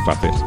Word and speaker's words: Faces: Y ahora pues nos vamos a quedar con lo que nos ha grabado la amiga Mireia Nascimento, que presenta su disco Faces, que significa igual que en inglés, Faces, pero se pronuncia Faces porque es portugués, y así que habla portugués muy bien, y español también, porque ha vivido en Barcelona.